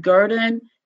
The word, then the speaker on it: Gurdon: So I grew up in Arkansas, specifically a small town named Gurdon, G-U-R-D-O-N. I always spell it because, you know, with the Southern accent, people wonder, now, what is she saying?